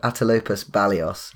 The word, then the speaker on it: Atelopus balios: It's called Atelopus balios. I'll keep talking. You find that. So it's spelled like eight lopus balios.